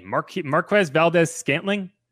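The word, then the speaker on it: Marquez Valdes-Scantling: Marquez Valdez Scantling.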